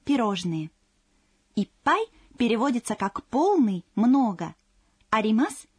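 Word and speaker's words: いっぱい: «Иппай» содержит удваиваемый согласный звук. Когда вы произносите это слово, нужно на секунду задержать дыхание.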